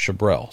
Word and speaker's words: Chabral: And I said, "Well, it's spelled Chabral, but it's pronounced Chabrel. So turn that last A into an E."